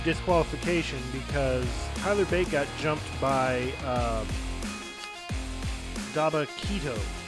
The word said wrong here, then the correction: Dabba-Kato: Daba, Dabba-Kato. Yeah, that's how you pronounce it.